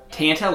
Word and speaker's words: Tanta: Tanta, like Santa? Oh, yeah.